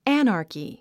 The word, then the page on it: anarchy: ǽnərki; アァナァキィ